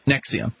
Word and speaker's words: NXIVM: Apparently, they pronounce it Nexium.